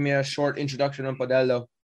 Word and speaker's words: Padello: Padello.